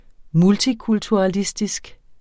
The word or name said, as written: multikulturalistisk